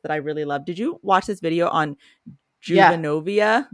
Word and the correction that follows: juvenoia: Like paranoia, but with juvenile in the beginning. – Juvenoia. – Juvenoia, thank you, which is this concept.